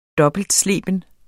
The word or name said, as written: dobbeltsleben